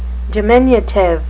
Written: diminutive

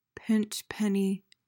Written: pinchpenny

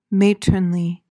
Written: matronly

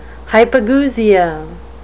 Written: hypogeusia

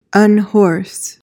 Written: unhorse

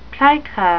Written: plica

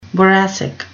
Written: boracic

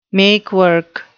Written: make-work

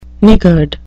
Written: niggard

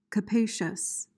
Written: capacious

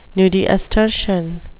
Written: nudiustertian

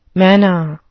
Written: manna

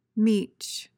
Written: meech

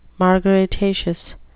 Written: margaritaceous

